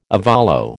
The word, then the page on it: ovolo: American English